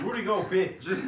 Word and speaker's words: Rudy Gobert: Rudy Gobert. – No, you said it right. – Rudy Gobert.